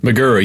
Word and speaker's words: McGerr: McGerr, yeah. I watched a bunch of videos of him actually saying his own name, and it's just McGerr. McGerr. Okay, it's not McGerr.